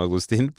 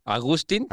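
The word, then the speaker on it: Agustin: Entonces yo me llamo Agustín, pero mis papás no lo registraron con acento en la I. Entonces soy Agustín. – ¿Agustín? – Así, Agustín. Así que bueno,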